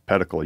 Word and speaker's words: pedicle: But Anthony Bubinick didn't coin the phrase pedicle, but he told us how to pronounce it, pedicle. He says it rhymes with medical.